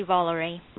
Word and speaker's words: Volary: No, it's actually Volare.